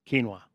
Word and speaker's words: quinoa: I call it quino. – You call it quino? – Quino. – Jeez. Okay. – Yeah, it's quino. Look it up. Tell me I'm wrong in how I pronounce it. – I know what it looks like. – Yeah, it's quino. – Quinoa. Yeah, okay.